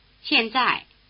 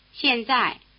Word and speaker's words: 现在: 现在. – Both syllables are in the falling tone. Repeat the word for now. – 现在.